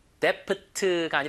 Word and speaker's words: debt: D 발음은 거의 무음이에요. debt가 아니라 그냥 debt. B 없는 것처럼 발음하세요. debt, debt, debt.